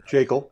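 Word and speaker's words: Jaeckel: Getting Richard Jackal. I'm not sure how you say it. – Jackal.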